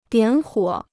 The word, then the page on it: 点火: diǎn huǒ